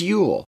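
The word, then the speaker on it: Jöl: Their book right now going on is called Yule, and that's spelled J O with double dots above it and L, but it's pronounced Yule.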